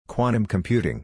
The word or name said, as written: Quantum Computing